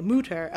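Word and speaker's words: Mütter: That's why I say Mutter, although no one actually knows how his name is pronounced.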